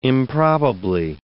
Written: improbably

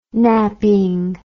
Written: napping

